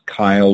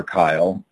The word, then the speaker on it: Kiel: My name's Kyle, but it's spelled KIEL.